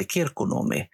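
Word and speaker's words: Kirkkonummi: Och de ehm, Eh, vi tillbringar ju en del somrar i Helsingfors eller dess utkant i en liten, liten ett samhälle som heter Kirkonomi. Ah. Titta. I, okay, okay. – Eh, eh, de säger att det är perfekt uttal.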